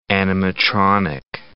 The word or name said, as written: animatronic